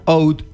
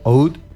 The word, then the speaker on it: oud: En Egipto se lo pronuncia oud, por ejemplo, oud. Y en Medio Oriente, sí, la puedo interrumpir nomás, total, yo estoy acá al Pepe, ¿no? Y en otros lugares de Medio Oriente lo pronuncian laúd.